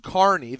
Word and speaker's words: Kearney: Pronounced Kearney, there it is.